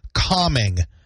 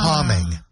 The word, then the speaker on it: calming: There's a silent L in today's word, Renee. Today's first word is calming. – Aww. – Calming. C-A-L-M-I-N-G, calming.